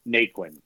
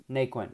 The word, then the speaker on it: Naquin: Is it Naquin? – Naquin. – Naquin.